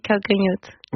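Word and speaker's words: Calcanhotto: Calcanhoto. – Oui, Calcanhoto.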